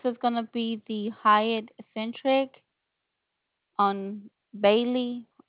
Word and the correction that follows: Beale: if I'm pronouncing that right, B-E-A-L-E Street. – Beale Street. Yep.